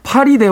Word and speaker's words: 파리: 다음 주에는 윌리엄 골딩의 파리 대왕 읽어보도록 하겠습니다. 여기서 파리라고 하면 이제 그 파리가 아니고요. 아, 파리입니다. 파리. 예, 예, 손발 비비는 파리. 예, 잘못 발음하면 파리 대왕이 돼서 예, 파리 대왕 읽어보도록 하겠습니다.